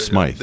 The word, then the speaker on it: Smythe: No, James, it's Smythe. – Right? Yes, exactly. That's what I would do. And our the H is silent.